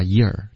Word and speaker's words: your: Your 的发音刚刚就不应该这么发，发 year 就行了。